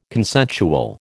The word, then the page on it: consensual: American English